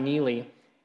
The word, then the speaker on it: Neely: His name is Adam Neely, with an N, not a silent K that you might expect. Adam Neely.